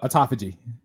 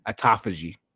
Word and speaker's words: autophagy: And by the way, this word is spelled autophagy. Is that how you say it? – Yeah, so autophagy. – Autophagy.